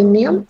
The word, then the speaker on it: мем: Интересно тут поговорить о слове «мем», с которым все наоборот. Правильное произношение, наоборот, мягкое.